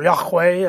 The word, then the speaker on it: Yahweh: In the Old Testament, they also use Yahweh or Yahweh, as it's pronounced.